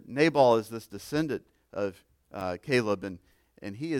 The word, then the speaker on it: Caleb: Caleb. He's a descendant of Cabal, that great man of faith. I said it wrong again, didn't I? Somebody say it for me right. Caleb.